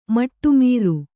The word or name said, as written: ಮಟ್ಟಮೀರು